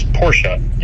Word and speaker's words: Porsche: So the company I work for is Porsche, and the reason that the name is pronounced that way is a little bit of a story.